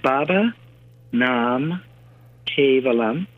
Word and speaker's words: Baba Nam Kevalam: So it's pronounced Baba Nam Kevalam.